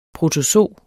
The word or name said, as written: PROTOZO